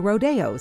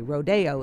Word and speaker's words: Rodeo: spelled R-O-D-E-O. you'd probably look at that and pronounce it rodeo. So would most people. So did Agnes DeMille. But for some reason, maybe because he'd been to Mexico where they say rodeo instead of rodeo, Aaron Copland called the ballet rodeo.